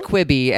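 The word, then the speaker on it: Quibi: You said, it's pronounced quibby, and it came out yesterday.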